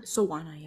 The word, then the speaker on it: Soana: Soana, yeah.